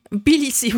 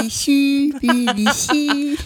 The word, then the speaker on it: Tbilissi: La capitale est Tbilissi. C'est un peu compliqué à prononcer, tu ne trouves pas ? – Tbilissi, oui, voilà. – Tbilissi.